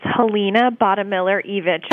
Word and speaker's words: Helena Bottemiller Evich: It's Helena Buttmiller-Evich, but you are certainly not the first person to get it wrong, so no worries.